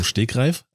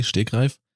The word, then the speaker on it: Stegreif: Das heißt, die meisten, das ist so ein Wort, was die meisten Leute auch falsch aussprechen, beziehungsweise falsch betonen dann. – Ja, richtig. – Das heißt eigentlich aus dem Stegreif. – Also du sagst es relativ hart, das G, Stegreif.